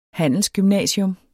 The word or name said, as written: Handelsgymnasium